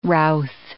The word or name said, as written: routh